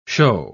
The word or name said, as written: shew